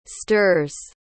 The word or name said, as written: stirs